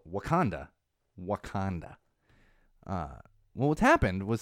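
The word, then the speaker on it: Wauconda: It is spelled differently, but it's called Wakanda.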